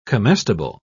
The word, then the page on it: comestible: British English